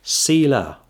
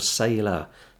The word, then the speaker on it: Selah: Selah, or Selah. Selah, Selah, it can be pronounced both ways.